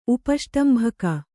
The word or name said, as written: ಉಪಷ್ಟಂಭಕ